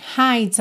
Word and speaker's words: hides: It hides a nasty stain that's lying there. It hides, atenção que o E é mudo, tá? Vai do D direto pro S.